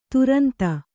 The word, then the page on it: ತುರಂತ: turanta